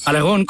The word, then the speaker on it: Aragorn: De Aragorn, de Aragorn, no de Aragorn. Aragorn con acento en la O.